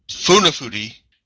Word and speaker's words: Funafuti: What the five more are, because they probably are like, hey, and I'm going to butcher this, and you're Funati Fudi, no, Funafuti, Yaren, and Tuarwa.